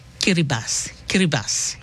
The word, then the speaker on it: Kiribati: Him pronounce the same Kiribati. Yeah. So the T-I in Kiribati him pronounce is S-I.